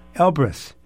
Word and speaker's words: Elbrus: E-L-B, as in boy, yeah. – R-U-S. – Elbrus.